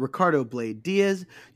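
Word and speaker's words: Ricardo Blayde Diaz: And I am Ricardo Blade Diaz.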